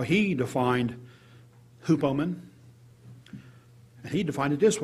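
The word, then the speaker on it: hupomone: It's H-U-P-O-M-O-N-E, with a little line above the E. The pronunciation told me it was hupomen. Hupomen. I may have butchered that.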